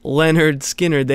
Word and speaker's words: Lynyrd Skynyrd: Before that, Lynyrd Skynyrd with I Ain't the One, the first track off their 1973 debut record, pronounced Lynyrd Skynyrd.